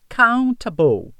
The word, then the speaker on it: countable: A pronúncia mais britânica fica countable. Countable. Eles pronunciam esse T com a vogal A, countable. O americano já tira esse som do T e fica mais um som de D, countable.